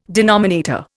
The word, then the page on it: denominator: Indian